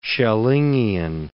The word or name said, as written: Schellingian